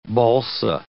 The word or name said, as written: balsa